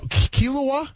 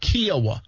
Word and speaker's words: Kiawah: Kiawah? – Kiawah.